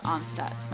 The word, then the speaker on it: Amstutz: Yes, and you can find me on all social media platforms at Sandra Amstutz.